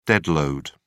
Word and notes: dead load: British English

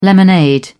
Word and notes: lemonade: British English

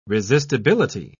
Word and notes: resistibility: British English

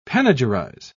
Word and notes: panegyrize: British English

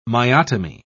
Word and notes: myotomy: British English

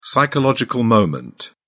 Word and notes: psychological moment: British English

